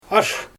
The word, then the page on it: ash: Xyzyl